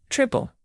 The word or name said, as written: triple